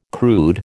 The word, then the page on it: crude: American English